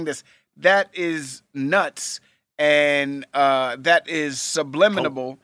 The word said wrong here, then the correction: subliminal: Subliminal. You added subliminal. Extra syllable.